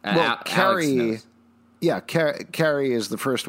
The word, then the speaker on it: Carrie: It's pronounced Carrie and Kerry. – Right. – It's yeah. Carrie. You're saying and Kerry. The same goddamn name twice. – No, no, no. There's a very distinct difference between the two pronunciations and the two characters.